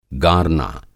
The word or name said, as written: गारना